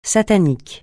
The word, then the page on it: satanique: francais